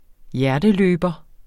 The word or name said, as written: hjerteløber